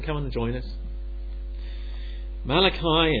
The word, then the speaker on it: Malachi: I guess in the Hebrew they would probably call this guy something like Malachi. Sort of, you know, they'd really pronounce that that CH a bit like loch, as the Scottish people do.